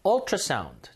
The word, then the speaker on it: ultrasound: 자, 요 발음이 어에 가까워요. 우, 유처럼 돼 있어가지고 읽을 땐 유를 읽을 것 같은데 어. Ultrasound. Ultrasound. 그래서 이 앞에 관사가 언입니다. 지난 시간에 urine이라고 하는 그 단어 있었죠? 소변 검사할 때 urine sample. 똑같은 u로 시작하는데, 그건 읽을 때 u로 읽으니까 앞에 관사가 어구요. A urine sample. 이거는 똑같은 u로 시작하는데 읽을 때 어로 읽어요.